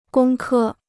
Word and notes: 工科: gōng kē

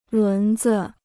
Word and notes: 轮子: lún zi

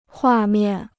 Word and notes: 画面: huà miàn

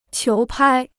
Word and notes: 球拍: qiú pāi

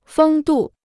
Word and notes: 风度: fēng dù

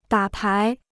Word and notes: 打牌: dǎ pái

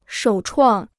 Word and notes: 首创: shǒu chuàng

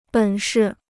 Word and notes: 本事: běn shi

